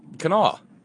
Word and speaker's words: Kanawha: Kanawha. K-A-N-A-W-H-A, Kanawha.